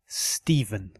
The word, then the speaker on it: Stephen: Stephen is pronounced with a V. Stephen.